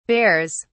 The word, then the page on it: bears: British English